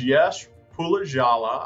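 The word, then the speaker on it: Yash Pulijala: This is Yash Pulajala.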